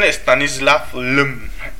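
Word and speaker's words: Stanisław Lem: El Solaris es una novela de ciencia ficción de, si lo digo bien, Stanislav Lem.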